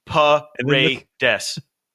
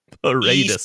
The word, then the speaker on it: Paredes: It's the letter E dash sock, like the sock on your foot. Paredes. – Paredes.